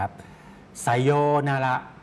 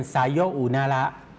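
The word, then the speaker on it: ไ ซโ ย นา ระ (さようなら): ไซโยนาระครับถ้าออกเสียงช้าๆช้าๆจะเป็นไซโยอุนาระครับแต่ออกเสียงรวบครับออกเสียงยาวไปก็จะออกเสียงว่าไซโยนาระ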